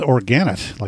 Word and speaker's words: Organit: Coming up in the next set is some IDM with Organit, like organic, but spelled with a T in the end instead of a C.